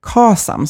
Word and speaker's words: KASAM: Och eh, de är ju som sagt ett stort fan också av att jobba med KASAM som det faktiskt uttalas, känsla av sammanhang. Och det är min också favoritmodell och din också tror jag.